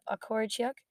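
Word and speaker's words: Okhotiuk: What I think about this trade, and I'm just gonna correct every single name you said there. It's Zachary Emmond and Santeri Hatica and Timur Abragimov, and then San Jose got Fabian Zetterlund and Nikita Okorchuk and Shakir Mukhamadulin. That one I don't know how to pronounce.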